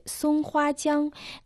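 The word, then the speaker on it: 松花江: Название реки Сунхуатянг на китайском языке состоит из трех иероглифов Сунхуатянг. Первый иероглиф Сун произносится в первом тоне, переводится как «сосна». Второй иероглиф Хуа произносится в первом тоне, в «цветы». Третий иероглиф Тян также в первом тоне означает «река».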